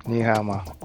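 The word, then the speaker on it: Ni hao ma (你好吗): Ni hao ma. – You heard it. We had Mandarin on the show. We did it. What? Whatever. – Ni hao ma.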